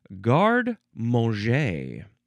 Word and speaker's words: garde-manger: Next we have garde-manger. Garde-manger. First word is G-A-R-D-E. Then there's a hyphen. Then it's the word manger. M-A-N-G-E-R. But it's pronounced garde-manger.